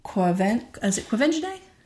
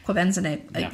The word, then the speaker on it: Quvenzhané: I loved the chemistry between Jamie Foxx and Quvenzhané Wallace. I'm so sorry for mispronouncing it. – Quvenzhané.